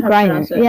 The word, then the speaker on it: Griner: Griner. Yeah, it's G-R-I-N-E-R.